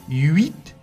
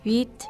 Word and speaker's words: huit: Mira, hay gente que tiene tendencia de decir huit. Huit. No es huit, es huit. La U, tiene que pronunciar la U. Huit. Escucha también alumnos y profesores a decir neuf. No es neuf, c'est neuf. N-E-U-F.